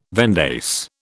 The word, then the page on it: vendace: British English